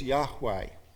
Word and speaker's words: Yahweh: The letters are YHWH because they didn't include the vowels in the written form. But we most likely should pronounce it as Yahweh.